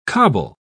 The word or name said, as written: cobble